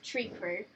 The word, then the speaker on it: Trikru: And then she would have stepped down, but still been involved, probably just as the head of Tree Crew. And... they could have... – Tree Crew. – So the clans have all got, like, names. – Okay. – So it's spelt differently. It's spelt T-R-I-K-R-U.